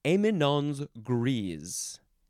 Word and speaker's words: éminence grise: Next is, this is a French phrase, éminence grise.